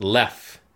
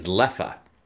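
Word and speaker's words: Leffe: So it's pronounced Lef. But the Dutch pronunciation is Leffa.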